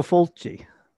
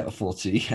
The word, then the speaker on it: Fulci: Uh, okay, so number three is Four for the Apocalypse by Luco Fluky, 1975. Uh, are you laughing because I pronounced that wrong? Lucio Fulci. Yeah, Fulci.